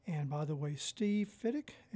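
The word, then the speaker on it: Fidyk: That was Steve Fittick's quintet from his new album Heads Up, and that was some beautiful flugelhorn work by Terrell Stafford.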